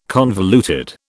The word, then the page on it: convoluted: British English